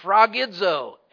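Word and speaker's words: sphragidzo (σφραγίζω): It is sphragidzo, S-P-H-R-A-G-I-D-Z-O, sphragidzo.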